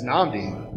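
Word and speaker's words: Nnamdi: It's Namdi. – Namdi what? – Adim. – Okay, keep going. – The hyphen usually gets dropped. I don't stop saying Namdi Adim.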